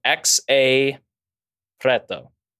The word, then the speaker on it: Xa Preto: I'm going to pronounce his name wrong, probably, but it is spelled X-A Preto, but I think it's pronounced, because my girlfriend's Brazilian, she helped me say it. I think it's pronounced Xa Preto. She's giving me a thumbs up.